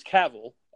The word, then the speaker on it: Cavill: I mean, this is definitely old news, but I wanted to get you guys on a speculation with Henry Cavill or Cavill. Sorry, I keep pronouncing that wrong. It's Cavill. And his stance on future Superman movies and what's going on with him.